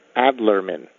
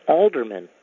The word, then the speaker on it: Adlerman: Hello, my name is Danny Adlerman. That's A-D-L-E-R-M-A-N. And I spell it out because many people pronounce it Alderman, even though it says Adlerman on our books.